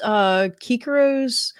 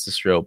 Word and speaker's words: Cicero: He does pronounce Cicero, but Kikero. The C is a little weird in Latin, so just in case you're hearing that, you think it's weird.